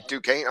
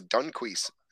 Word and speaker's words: Duquesne: It's Duquesne. I always thought it was Dunquise.